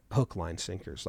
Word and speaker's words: Hook, Line, Sinkers: You say it like one word. And I said, Hook, Line, Sinkers, like Brookline, Hook, Line, Sinkers.